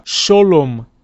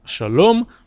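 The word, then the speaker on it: шалом (שלום): Шолом алейхем, шолом вместо шалом, шолом алейхем, малахея шорейс, малахей ильон, Мелех малахея млохим, опять же, млохим, акодойш борух у. В ашкенадском произношении огласовка камац э, передается как о, произносится как о.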